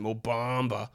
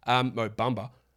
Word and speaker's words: Mo Bamba: I can't, unless you want me to say it with an American accent, Mo Bamba, which again is not how it's pronounced. Mo Bamba.